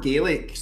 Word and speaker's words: Gaelic: even the word Gaelic, some people say Gaelic here, so it's Gaelic.